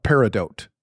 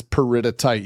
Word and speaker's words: peridotite: So one thing, Jesse, when my students see peridotite, I mean, they've heard me pronounce it several times, but when they read it, the most common thing that they see, they call it peridote. Like, no, that's a gemstone. This is peridotite. You got a couple syllables you got to slip in.